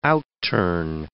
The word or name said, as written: outturn